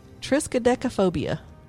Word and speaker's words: triskaidekaphobia: It's triskaidekaphobia.